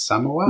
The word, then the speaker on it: Samoa: I'm always pronouncing Samoa wrong. It's Samoa. – No, you're pronouncing it right, yeah.